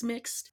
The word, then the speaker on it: Mx: And I asked them just for verification, like, hey, is MX pronounced mix or Ms. with a Z pronunciation? And they said, no, it's mixed.